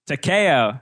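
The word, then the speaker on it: Takei: Let's talk about, how do you pronounce this? The Takeo? – Takeo. – As in George Takeo? – That's right.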